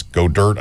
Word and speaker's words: Goedert: The best one in the country is the kid from South Dakota State, Dallas Goedert, I think is how you pronounce his last name.